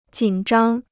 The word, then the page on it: 紧张: jǐn zhāng